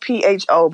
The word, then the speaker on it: pho: It's spelled pho, but it's pronounced pho.